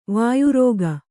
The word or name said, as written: ವಾಯುರೋಗ